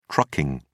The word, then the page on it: trucking: British English